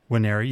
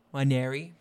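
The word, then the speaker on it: Nwaneri: Wanneri, yes. – Got a friend who tells me that the silent N is a thing. So, Wanneri, you know what I'm saying? – Wanneri.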